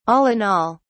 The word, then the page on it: all-in-all: British English